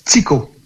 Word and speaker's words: Tycho: Un cratère qu'on peut voir à l'œil nu, c'est le cratère de, et là j'espère bien le prononcer, Tycho ou Tycho? – Tycho. – Tycho, oui.